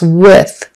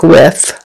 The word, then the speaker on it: with: For instance, with is commonly mispronounced with an F on the end, like with. And so you're going to practice with.